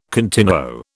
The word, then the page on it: continuo: British English